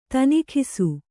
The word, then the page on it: ತನಿಖಿಸು: Kannada; tanikhisu